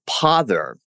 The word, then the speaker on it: pother: And pother. – Yes. – That was a good one too. Pother. It sounds like bother, but it's spelled with a P.